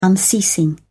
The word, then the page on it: unceasing: British English